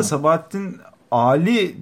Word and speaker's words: Sabahattin Ali: Bu arada Sabahattin Ali diye okunuyor ama bunu böyle okuyan çok yok. Bu da biraz bir tartışma konusu. Hani Türkçe'de onu bunu böyle okumak, hani artık uzatmaları okumuyorlar bazı yerlerde.